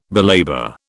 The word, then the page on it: belabour: British English